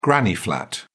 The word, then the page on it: granny flat: British English